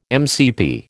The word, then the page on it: MCP: American English